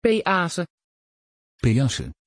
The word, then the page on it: peace: Dutch